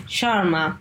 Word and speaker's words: Sharma: Okay, so it's Olga Maciaszek Sharma. Usually the last part of my surname is the easiest to pronounce because Sharma, yeah, most of English speakers can pronounce Sharma, while Maciaszek is sort of difficult.